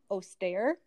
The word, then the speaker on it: Eostre: There's many traditions all over the world, but we are going to just go over one of these festivals, which was called Ostare, and it's spelled E-O-S-T-R-E.